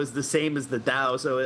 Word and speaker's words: Tao: Well, actually, the Mandarin spelling should be a D. It's pronounced Dao in Mandarin, right? But it's written T in English because of, I think, the old Wade Giles way of spelling it or whatever.